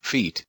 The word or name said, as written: feet